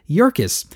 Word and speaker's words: Yerkes: I've often heard the name pronounced Yerkes. That's how I often said it until I was in my bank not long ago and noticed that the banker I was talking to had the last name Yerkes. And I asked him, how do you pronounce that? And he said, Yerkes. Well, he should know, so I will go with Yerkes.